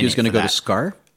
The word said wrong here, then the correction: Sakaar: Sakaar. – Yeah. – Okay, I thought it was two syllables, my bad. – No, it's Sakaar.